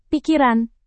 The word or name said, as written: pikiran